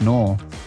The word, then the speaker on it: Newell: I mean, every time I go to the tanning bed, I say Adam Knoll. And they're like, Knoll? I'm like, Knoll. And they're like, how do you spell that? And I'm like, N-E-W-E-L-L. They're like, okay, Mr. Newell. I'm like, I don't know why you're saying my name all funny and shit. That's not how you say it.